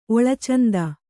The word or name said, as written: ಒಳಚಂದ